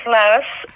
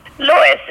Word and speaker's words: Lois: My name is Lois, or Lois Sophia Griffiths. Lois, it rhymes with Joyce, because my father was an Englishman, and that's how it was pronounced there.